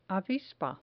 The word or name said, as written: avispa